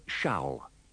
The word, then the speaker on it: shall: In these dialogues, pay attention to the pronunciation of shall. It is usually pronounced with a schwa.